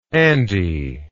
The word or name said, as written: Andie